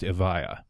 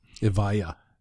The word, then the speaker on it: Evija: And it keeps in with the tradition they've been running since the 50s of giving their cars names that start with E, but apparently it's pronounced Evija. – Evija.